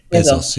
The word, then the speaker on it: Bezos: I can never pronounce his name. – Bezos. – It's a bit embarrassing. – Bezos. Yeah.